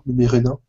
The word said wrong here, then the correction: Numenera: Numenera, ouais. – Ouais, j'y étais presque.